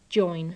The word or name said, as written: join